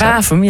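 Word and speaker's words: Warffum: Spreek je dat op zijn Gronings? – Wavum, ja.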